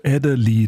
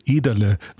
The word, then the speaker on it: Ederle: Gertrude Adderley, da habe ich jetzt auch wieder was dazugelernt. Ich hätte nicht auf meine Oma hören sollen, die mir gesagt hat, Gertrude Ederle wird sich ausgesprochen.